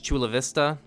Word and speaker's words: Chula Vista: This is just another one, pretty much Chula Vista, which I hope I said that correctly. – You did.